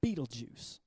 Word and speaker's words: Betelgeuse: And there's a star in the sky called Betelgeuse. It's spelled different than Michael Keaton, but Betelgeuse.